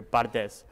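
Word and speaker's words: Valdés: Bardes，虽然他的那个是 V，但是在西班牙语的发音里面这个是 B 的音，所以是 Bardes。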